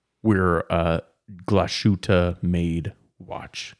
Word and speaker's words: Glashütte: Made in Glashütte. Glashütte is how you pronounce that.